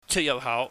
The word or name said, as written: cheutyahphau